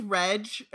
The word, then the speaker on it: Reg: I think it's Reg, or at least that's how they say it later. – Oh, Reg. Yeah, that makes more sense. Yeah. Like, short for Reggie. Yeah, that'd make a lot more sense than Reg.